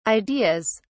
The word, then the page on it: ideas: British English